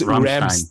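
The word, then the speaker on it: Rammstein: Is that, it is Ramenstein, right? – It is Ramstein. – Ramstein. – Okay. – Originally, it's Ramstein, yeah? – Okay. – It's Ramstein.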